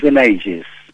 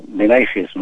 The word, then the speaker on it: Benaigues: Bueno, en catalán es Benaigues. – Benaigues. – Benaigues. Pero en castellano se puede pronunciar Benaigues, ¿no? Porque la G suena así como más fuerte. – Sí. – Pero en catalán es Antoni Benaigues.